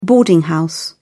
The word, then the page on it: boarding house: British English